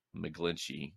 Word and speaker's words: McGlinchey: and their right tackle Mike McGlinchey. I believe is how he pronounced his last name.